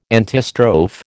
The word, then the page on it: antistrophe: American English